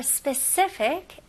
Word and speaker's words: specific: 여기서는 발음은 specific. 마지막 F 발음을 명확히 하실 거면 입술이 붙으면 안 되겠죠? Fic. Specific. Specific. Specific. 네, 강세는 C에 들어갑니다. 네, specific.